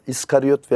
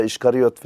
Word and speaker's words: İskariyot: 13 havariden birisi Yahuda, İskariyot veya İşkariyot filan diye telaffuz ediyorlar.